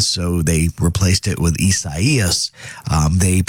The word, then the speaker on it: Isaias: Survived the light rain of Hurricane Isaias here in the Midlands.